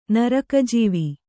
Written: ನರಕಜೀವಿ

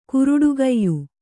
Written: ಕರಟುಕಾಯಿ

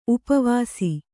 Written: ಉಪವಾಸ

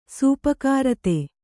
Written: ಸೂಪಕಾರತೆ

